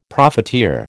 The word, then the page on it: profiteer: American English